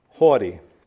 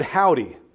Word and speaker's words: haughty: For years I would read this scripture, I would read this text, and I pronounced this word, howdy. I thought that's how it was pronounced.